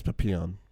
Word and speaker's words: Papillon: Is it Papillion? I never know how to pronounce that word. – Papillon? – Papillion? Papillion? – No, I think it's Papillon.